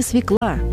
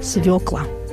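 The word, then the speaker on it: свёкла: Я, ребята, не свекла, я, ребята, свекла.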